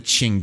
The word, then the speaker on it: Qing: And we call them the Qing dynasty. So it's spelled Q-I-N-G.